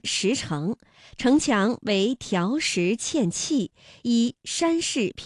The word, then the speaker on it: 条石: 那本来两个相连呢还应该是两个三五啊，但是呢考虑到连读需要变调，那么第一个字就读成了三四，第二个依旧读三五，比如条石。